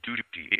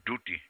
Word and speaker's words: dhuti: But actually, it specifically says in the Chaitanya Bhagavat, dhuti, even in Chaitanya Charitamrita, it says the word is used with the U, dhuti. The pronunciation is slightly different now, dhoti. When Sanatana Goswami had to go and change, it said he took a dhuti, D-H-U-T-I. Rather than a dhoti.